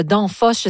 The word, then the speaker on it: Danfoss: Alors, On parle d'une compagnie de logiciels danoise, le Danfoss, je ne sais pas si je le prononce comme il faut. – Oui, mais tout à fait.